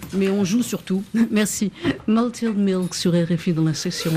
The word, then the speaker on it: Malted Milk: Oh, comment vous prononcez, Arnaud ? – Malted Milk.